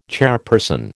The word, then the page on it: chairperson: American English